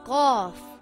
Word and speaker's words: Qaf: Qaf is a heavy letter and should always be pronounced heavy. It sounds a little like a crow sound. Qa, the sound does not make a K sound. It comes from the back of the mouth.